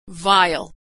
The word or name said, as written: vile